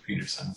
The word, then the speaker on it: Pederson: So it looks like Pederson, but it's pronounced Peterson.